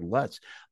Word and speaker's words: Lutz: Now, let me ask you this, because I thought for years it was Greg LUTZ, L-U-T-Z, Greg LUTZ.